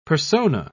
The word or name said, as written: persona